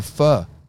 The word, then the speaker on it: pho: It's pronounced pho. – Out of here, brother. – Any of our Vietnamese listeners, if you could put in the comments I'm sure there's multiple. – do like a voice recording of actually how to pronounce pho because I don't really know how to do it.